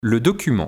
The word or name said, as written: Le document